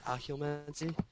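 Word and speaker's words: Occlumency: How do you pronounce that? Oculomancy? – Yeah, oculomancy.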